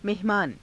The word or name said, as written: mehamaana